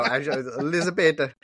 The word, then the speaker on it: Elisabetta: That's how I'm just going to say it because that's how he pronounces it. – You're making him sound Italian. – I know. Elisabetta.